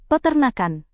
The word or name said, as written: peternakan